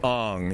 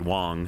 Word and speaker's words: Wang: So there's that guy, and then there's a short-braid Wang. – Let's make up your own material. – I actually do think A-N-G is pronounced Ong. You know, so it's Shanghai. – Short braid Wong.